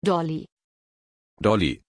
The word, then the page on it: Dolly: German